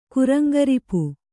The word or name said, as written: ಕುರಂಗರಿಪು